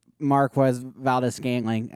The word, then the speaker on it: Valdes-Scantling: Yes, you heard it every which way, but Marquez Valdes-Scantling.